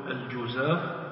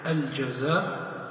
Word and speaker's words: الجزاف: وكلمة الجزاف هي كلمة مثلثة الجيم، مثلثة الجيم بمعنى أن جيم هذه الكلمة تجوز فيها الحركات الثلاثة، يعني يجوز أن تنطق بالضم تقول الجزاف، ويجوز فيها الفتح الجزاف، ويجوز فيها الكسر الجزاف، والكسر أفصح كما قال بعض العلماء.